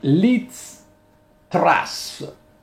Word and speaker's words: Liz Truss: Si tratta di Liz Truss, penso si pronunci così, è l'ex eh, ministro degli esteri del governo eh, Johnson.